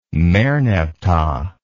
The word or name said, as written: merneptah